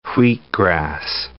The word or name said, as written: wheatgrass